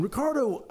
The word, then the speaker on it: Ricardo: And I'm not rolling that R at all. It's properly pronounced Ricardo, much cooler, and I'm one of these days. And Ricardo, I don't know how he does it, but he always looks put together.